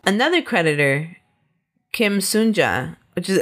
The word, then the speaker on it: Sun-ja: Our antagonist, our bad guy, is Kim Sunja. And this is Kim Sunja. – Kim Sunja and Kim Sunja. – Ours is spelled S-U-N. And this victim is S-O-O-N.